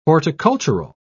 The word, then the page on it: horticultural: British English